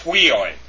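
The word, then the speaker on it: Huioi (υἱοί): We should know that a rough breathing mark is to the bends to the right, and not a rough breathing mark bends to the left. So it's pronounced Huioi.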